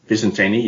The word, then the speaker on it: Vicentini: And Vicente, I think, is that how you say it? Vicente, the ruckman? – Vicentini, yeah.